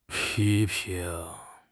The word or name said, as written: pi1 ping2